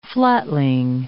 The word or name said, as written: flatling